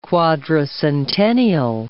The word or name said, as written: quadricentennial